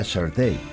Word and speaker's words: Sade: ¿Te acuerdas que el otro día aprendimos que se escribe Sade, pero se pronuncia Shortay? – ¿Pero por qué?